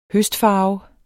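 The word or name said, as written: høstfarve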